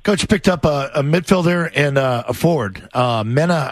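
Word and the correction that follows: Mané: I'm sorry if I pronounce his name wrong, but is it Mena and McNamara? – It's Kakuta Mane.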